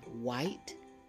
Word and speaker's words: white: Here are some more words that start with WH, where the H is silent. We have white and whistle. Say the words after me. White, whistle. Did you hear the H sound? No, because the H sound is silent.